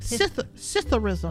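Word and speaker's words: psithurism: Citherism.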